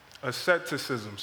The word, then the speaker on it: asceticism: So I'm going to argue that Paul is warning in our text, he's warning the Colossians against a false teaching of asceticism. Asceticism. Sorry, this is a tongue twister, so if I butcher that word this whole time, just ignore that. – You got it right.